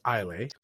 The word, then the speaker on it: Islay: So it's pronounced Ile. Ile, whatever.